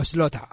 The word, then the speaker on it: Slaughter: Slaughter. Ya, o Slota, si queréis pronunciarlo así, a lo americano, Slang, Slota, House of Slota.